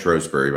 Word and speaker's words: Shrewsbury: Yes, pronounced Shrewsbury by the locals.